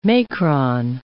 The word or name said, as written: macron